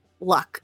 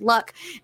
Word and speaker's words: Lugh: and his name was Luck, is how you pronounce it. It's spelled L-U-G-H, but it's pronounced Luck.